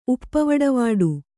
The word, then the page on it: ಉಪ್ಪವಡವಾಡು: kannada